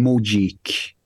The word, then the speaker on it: Mogeek: And the two winners are, this is really the best one, Mojik. Now, this name alone ensures that they'll always stay obscure, but it's spelled M-O-G-E-E-K, okay?